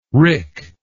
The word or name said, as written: wrick